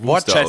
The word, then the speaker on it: Worcester: ja genau, diese, Worcester? – ja, was ist da? Das wird ja Wooster ausgesprochen. Und dann hat jemand halt einfach Worcester genommen, aber ein B vorne dran gehängt als Booster-Impfung.